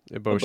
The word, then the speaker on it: Eboshi: And I thought, um, like, um, Lady, forgive me for mispronouncing, Lady Eboshi. Uh, Eboshi? – Eboshi.